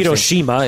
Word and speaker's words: Hiroshima: Hiroshima is the way to pronounce it.